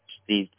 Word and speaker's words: Steve: Steve Zafel. – Sorry, man. I'll get you to spell it for me. – S-T-E-V-E.